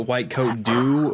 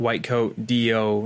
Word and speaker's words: WhiteCoatDO: So is that, do you pronounce it White Coat Do, or is it like White Coat DO, like standing for something? – Oh, White Coat DO. And the DO stands for Doctor of Osteopathic Medicine.